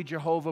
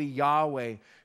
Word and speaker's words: Yahweh: In Hebrew, that would be pronounced either Yahweh, possibly Jehovah, but probably Yahweh.